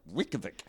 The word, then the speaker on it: Reykjavik: I debuted it at the Reykjavik Fringe in Iceland. – Okay. – That is apparently how they pronounce it. Caught me out too. So Reykjavik. That was great fun, but also, of course, a very international audience, people from all over.